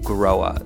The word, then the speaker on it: Grohe: There is, and the company that is behind it is called Groa. It's spelled G-R-O-H-E, but pronounced kind of like you grow a flower.